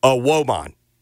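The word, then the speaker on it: Evbuomwan: It was indeed a Woman.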